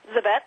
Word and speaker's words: Zabet: Zabet, that's it.